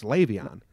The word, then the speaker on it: Le'Veon: I admit I was saying it wrong before. Now I found out how to say it. It's Le'Veon.